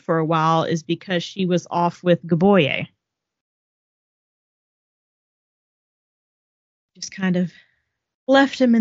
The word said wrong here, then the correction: Boyega: Boyega. Boyega. Hang on. Boyega. Boyega. Sorry, I was listening to how to pronounce.